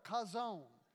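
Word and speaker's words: Kazon (חזון): Kazon is one of those Hebrew words that is interesting because to truly say it right, you have to say it almost like you're clearing your throat. Kazon.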